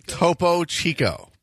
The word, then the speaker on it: Topo Chico: Topo Chico.